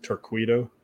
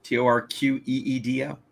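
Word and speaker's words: Torqeedo: I believe it's pronounced Torquedo. – But it's... No, there's no U. Torqueedo. There you go.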